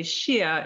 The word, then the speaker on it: Sia: Now it's pronounced technically Shia in Scottish Gaelic, but it's my brand and there's no H in it, so I could call it whatever I want, right?